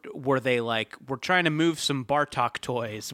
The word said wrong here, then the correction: Batroc: Well, first off, I hate to correct a pronunciation, but it is Batroc. – I'm sorry. – I think this might give you a clue as to who wanted him in the book. – It's pronounced Batroc, but you might know it as Batcore.